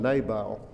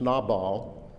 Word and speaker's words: Nabal: I've often called him Nabal, but it should be pronounced Nabal.